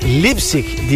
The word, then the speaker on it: Leipzig: Lipsi, onu sordum. Nasıl okunurmuş? Dur bakayım. Haşim'e. Lipsik diye okunur.